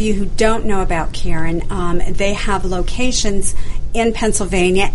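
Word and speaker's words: Caron: So very important, if you just want to know about Karen, let me spell it for you. It's C-A-R-O-N.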